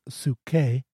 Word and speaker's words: Psyche: It actually pronounces Psuche, but it tends to be the word P-S-Y-C-H-E, and the myth of Cupid and Psuche, or is actually generally pronounced Cupid and Psyche.